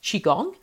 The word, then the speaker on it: Qigong: The Qigong is pronounced Qigong, and it was developed in China thousands of years ago as part of the traditional Chinese medicine system.